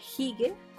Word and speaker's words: Hygge: Es, se escribe Hygge como el Hugo, tal cual Hygge Krog K-R-O-G.